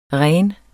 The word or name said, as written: ren